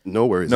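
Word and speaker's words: NxWorries: How do you pronounce next? – No worries. – No worries. – No worries. Okay. Yeah. I always see the X.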